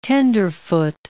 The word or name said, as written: tenderfoot